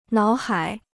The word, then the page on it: 脑海: nǎo hǎi